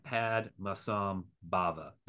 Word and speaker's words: Padmasambhava: So why did Padmasambhava, is that how you pronounce it, right? – Yeah, Padmasambhava. That is okay. About as close as my English-speaking tongue can ever get.